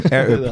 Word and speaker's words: Air Up: Je moet de R moet je goed doen. Air Up. – Hard, okay.